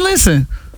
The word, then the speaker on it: listen: Listen is another one where the English words has a silent letter. – Silent and listen. – T. Listen. You ain't no T in listen.